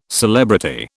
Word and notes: celebrity: British English

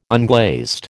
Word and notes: unglazed: American English

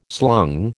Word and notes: slung: American English

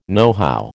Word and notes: nohow: American English

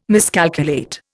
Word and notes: miscalculate: Indian

